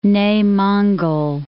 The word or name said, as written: Nei Monggol